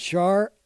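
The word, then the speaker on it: Ciaramella: Sharmela, C-I-A-R-A-M-E-L-A-A. It's pronounced Sharmela.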